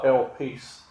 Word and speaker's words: elpis: If I say that right, is the English spelling is E-L-P-I-S, but the pronunciation guide gave me elpis.